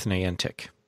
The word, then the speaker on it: Niantic: Let's go with Niantic.